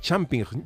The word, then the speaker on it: champignon: Sí, pero está, escrito en francés, que es champignon.